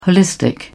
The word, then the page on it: holistic: British English